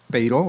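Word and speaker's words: Peiró: Así es, pues por fortuna hay muchos elementos, Peiro, originalmente el apellido con acento en la O.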